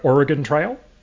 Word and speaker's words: Oregon: To talk about Oregon Trail. – Okay, I guess I can't throw you under the covered wagon there, because that was pronounced correctly.